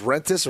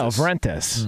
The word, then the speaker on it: Vrentas: Wait, you don't pronounce the V in her name? – Vrentas. Isn't that You're saying Vrentas. – Yeah. – Is it just Rentas or Vrentas? – No, Vrentas. Mm. Yeah, with the V.